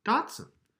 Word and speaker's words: Datsun: But I think the dat is pronounced dot. Datsun.